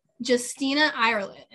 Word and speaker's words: Justina Ireland: And today we are going to be talking about Dread Nation, and that is by Justina Ireland.